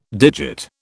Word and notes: digit: British English

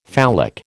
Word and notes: phallic: American English

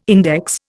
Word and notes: index: Indian

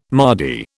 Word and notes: mardy: British English

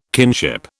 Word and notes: kinship: British English

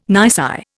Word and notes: nisi: Indian

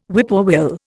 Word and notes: whippoorwill: Indian

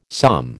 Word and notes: psalm: American English

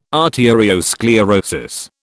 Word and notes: arteriosclerosis: British English

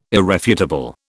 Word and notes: irrefutable: British English

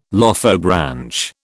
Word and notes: Lophobranch: British English

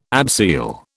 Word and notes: abseil: British English

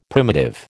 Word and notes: primitive: American English

